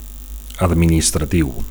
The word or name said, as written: administratiu